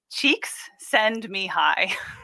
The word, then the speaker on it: Csikszentmihalyi: He likes to say that it's cheeks send me high. And that's how you remember how to say it.